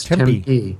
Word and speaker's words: Tempe: It's kind of tricky to pronounce. It really is. I mean, I've been told you put equal emphasis on both syllables, so it's Tempe.